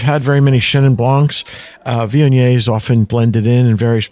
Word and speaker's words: Viognier: So the Chenin Blanc and the Viognier, if I said it right, Viognier.